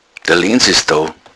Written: lenz

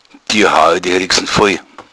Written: rixen

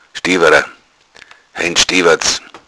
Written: stoeberer

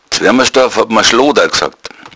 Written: schlooda

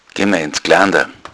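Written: Geländer

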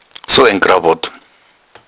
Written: krawatt

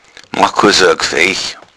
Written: gfaich

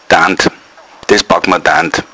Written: dand

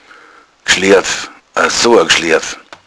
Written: gschlerf

